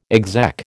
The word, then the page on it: exec: American English